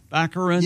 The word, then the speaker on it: Baccarin: Baccarin.